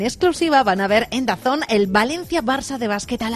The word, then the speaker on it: DAZN: DAZN, pero ya sabes que se pronuncia Dazón.